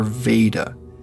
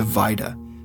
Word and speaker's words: Vaida: She'd actually jotted down a name for it and spelled it V-A-I-D-A. She wasn't sure how to pronounce it, so she began calling her Veda. Later on, you'll hear that she's corrected this pronunciation to Vida. I just wanted to clarify this early on.